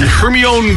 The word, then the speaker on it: Hermione: And Hermione Granger. And I never knew until, like, maybe about 3 weeks ago how to pronounce that. – Hermione.